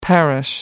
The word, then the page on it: parish: American English